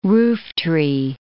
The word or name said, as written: rooftree